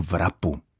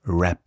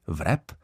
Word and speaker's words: wrap: Správně anglicky by se to slovo mělo vyslovit bez počátečního v, tedy jako rap. Ku podivu je to slovo anglické a jeho volný překlad by mohl být zámotek, protože pochází z anglického slovesa wrap, které znamená ovinout, omotat, obtočit. Tedy anglofonní svět si přivlastnil a po svém pojmenoval exotickou pochoutku původně nejspíš mexického nebo indického původu. Dodejme ještě, že počeštěná výslovnost, tedy vrep, je v pořádku.